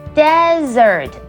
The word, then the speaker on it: desert: OK，那有两个 S 的那个字是念 dessert，也就是饭后的甜点，以及一个 S 的 desert 则是指沙漠。但是你有没有发现，其实他们发音完全不同，虽然只有差一个字母，dessert 甜点，desert 沙漠，有没有觉得差很大呢？